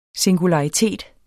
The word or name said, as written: singularitet